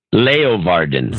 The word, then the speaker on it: Leeuwarden: Leeuwarden.